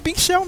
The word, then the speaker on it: Shell: é, é Shell mesmo, cara.